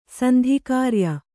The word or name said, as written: ಸಂಧಿಕಾರ್ಯ